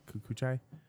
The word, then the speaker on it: Kikuchi: Rinko, I don't even know how to say her last name. K-I-K-U-C-H-I. Kikuchi? Kikuchi? – I'm sorry. Where's it at? – Anyway. – Oh. – Kikuchi sounds right.